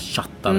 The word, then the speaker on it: chatta: En del säger, ska vi chatta lite grann och sånt? Tala inte om köta nu, alltså. – Precis. – Och, nej, det ska heta chatta verkligen.